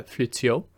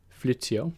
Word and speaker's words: Fleutiaux: And then they move to their third novella, which is uh, The Story of the Abyss and the Spyglass by Pierrette uh, Fleutiaux. Fleutiaux, I believe that's how you pronounce it.